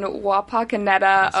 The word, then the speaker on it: Wapakoneta: And you grew up in Wapakoneta, Ohio.